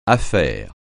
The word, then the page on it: affaire: French